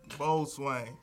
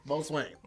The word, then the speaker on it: boatswain: Your Missouri accent is making you pronounce this right because the T is really silent. – I knew that. – Boatswain. – Boatswain.